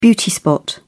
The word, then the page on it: beauty spot: British English